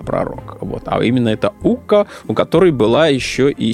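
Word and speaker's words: Ukko: Ука, да? И иногда этого... – Хорошее финское произношение.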